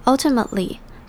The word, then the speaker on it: ultimately: 接着我们来看 ultimately 这个字， ultimately 这边第一个字首的 u 读成 a 的声音，而第二个重点是后面的 ti 这边的 i 是读成 a，而很特别的地方还有后面的 mate 这边的 a 是读成 e 的声音，所以是 ultimately 念快一点，在句子中听起来是 ultimately。